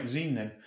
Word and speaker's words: zine: Z-I-N-E. – What, like zine? – Is that how that's pronounced? – Like magazine? – I guess. All right, zine then.